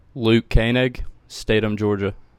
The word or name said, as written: Luke Koenig